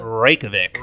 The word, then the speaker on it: Reykjavik: How do you pronounce it? – Reykjavik.